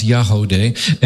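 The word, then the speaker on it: Yagody: It's Y-A-G-O-D-Y, but it's pronounced Yahodeh.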